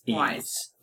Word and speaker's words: Ys: So it's Ease.